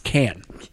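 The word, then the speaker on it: Cannes: Yeah, because I'm going to Con. – Oh, wow. So, cool. – Yeah. – I think it's actually pronounced Can.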